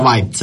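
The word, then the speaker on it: Vybz: come si pronuncia Vibes, io avrei detto Vibes, Susanna diceva Vibes, allora abbiamo detto Vibes.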